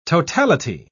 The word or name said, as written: totality